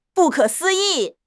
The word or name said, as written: 不可思议